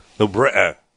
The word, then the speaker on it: New Britain: New Britain.